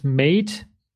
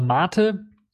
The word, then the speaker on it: MATE: Also was wird mit Mate oder Mate, je nachdem, wie man es aussprechen möchte,